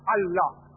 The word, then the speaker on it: Allah: They write it as I-L-A. But when you pronounce it, we have to tell them, pronounce it as Allah.